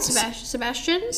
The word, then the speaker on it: Sebastian: At the moment, my favorite is Sebastian. Sebastian. I'm sorry, I cannot pronounce it. No, that was right. Sebastian.